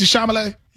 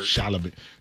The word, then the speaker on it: Chalamet: Okay, so yeah, well, that's three syllables, but let's put them all together into a word. – Chalamet. Timothy Chalamet. Chalamet.